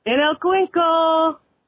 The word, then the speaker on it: en el cuenco: En el cuenco.